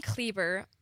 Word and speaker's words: Kleber: So my last name, Kleber, it just rhymes with Justin Bieber.